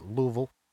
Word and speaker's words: Louisville: I believe they pronounce it Louisville, Kentucky. – Yeah, Louisville.